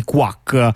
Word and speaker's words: QWAC: si scrive Q. Eh, WAC, eh, però quindi l'ovvia pronuncia è quack come, eh, le, mm, come, come chi, le, papere.